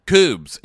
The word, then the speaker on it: Kubs: And Koobs, it's spelled K-U-B-S, rhymes with boobs.